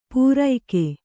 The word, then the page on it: ಪೂರೈಕೆ: pūraike